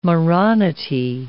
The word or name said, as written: moronity